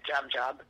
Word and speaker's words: Jab Jab: So it's the Jab Jab is from the French word diable, so you can hear the closeness in the Creole pronunciation of it, Jab, and then they double it, Jab Jab.